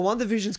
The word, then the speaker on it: WandaVision: WandaVision.